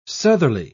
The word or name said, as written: southerly